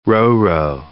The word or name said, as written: Ro-Ro